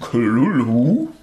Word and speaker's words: Cthulhu: Das heißt, dieses H in, in Klulu, das spricht man gar nicht.